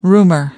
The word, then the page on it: rumor: British English